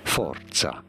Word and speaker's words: Forza: This is a word from Italian, meaning force in Italian. For reference, in Italian, this is said as forza.